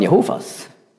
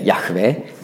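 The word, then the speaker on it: Jahwe: Und genau das leugnen zum Beispiel die Zeugen Jehovas. Die sagen, Jehova, so sprechen sie über Gott. Die falsche Aussprache, richtig wäre Yahweh.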